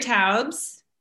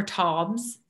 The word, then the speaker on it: Taubes: Gary Taubes, or Taubes, I think is the correct pronunciation.